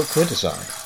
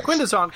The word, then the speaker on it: Quintesson: Not Quintesson, Quintesson. – Yeah, I forgot that they refer to them as things a little odd. – Like, well, they pronounce them Britishly. – They put the emphasis on the wrong syllable.